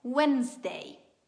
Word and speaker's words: Wednesday: La pronuncia corretta è Wednesday. Wednesday. Vi suggerisco di suddividere la parola in due parti, come abbiamo fatto con la precedente.